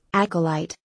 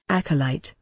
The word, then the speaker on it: acolyte: Acolyte in British English, acolyte. – Yeah, American English is more of an acolyte, whereas the British is acolyte. You barely even pronounce the O in acolyte.